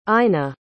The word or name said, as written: ina